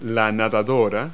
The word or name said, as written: swimmer